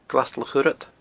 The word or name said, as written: glastulachthurad